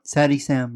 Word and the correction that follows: Yosemite Sam: That's not correct. – No? – Your voice. – It's Sam. Something Sam. Let me see it. You're not saying it right. It is something Sam. Yosemite Sam.